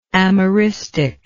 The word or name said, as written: ameristic